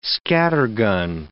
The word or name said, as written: scattergun